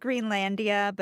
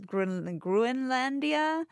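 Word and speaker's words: Groenlandia: It looks like Greenlandia, but it's Gruenlandia.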